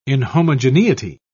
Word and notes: inhomogeneity: British English

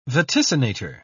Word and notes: vaticinator: British English

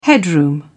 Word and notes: headroom: British English

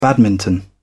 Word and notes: badminton: British English

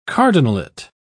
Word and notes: cardinalate: British English

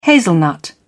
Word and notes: hazelnut: British English

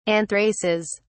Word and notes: anthraces: British English

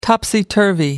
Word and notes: topsy-turvy: American English

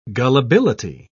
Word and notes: gullibility: British English

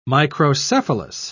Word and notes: microcephalous: British English

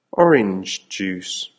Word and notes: orange juice: American English

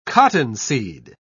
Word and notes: cottonseed: British English

